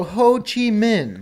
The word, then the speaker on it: Ho Chi Minh: So, Ho Chi Minh.